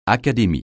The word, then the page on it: académie: francais